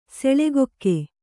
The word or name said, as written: ಸೆಳೆಗೊಕ್ಕೆ